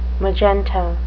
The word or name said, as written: magenta